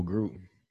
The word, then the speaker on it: Gruden: John Gordon. I don't think I'm saying his name right. What the fuck is the Raiders coach name? – Oh, Gruden. – John Gruden played for the motherfucker.